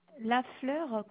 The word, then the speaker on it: Lafleur: Madame Lafleur Sylvie. – La fleur, comme ça se Oui. – prononce? – Oui, Lafleur et Sylvie. – Ah, sans U, ok. – Lur. – L-E.